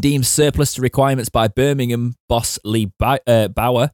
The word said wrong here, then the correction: Bowyer: it's Bowyer, isn't it? I can always say that wrong. Bowyer, thank you.